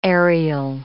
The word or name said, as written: aerial